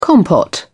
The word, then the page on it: compote: British English